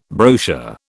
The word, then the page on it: brochure: British English